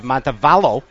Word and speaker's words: Montevallo: You guys are both at University of Montevallo. – Yes, sir. – Did I say it right? – You said it right, man.